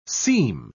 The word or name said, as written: seem